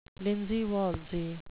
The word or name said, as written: linsey-woolsey